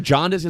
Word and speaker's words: jaundice: Am I just pronouncing jaundice? It's supposed to be Mama Jaundice, sorry.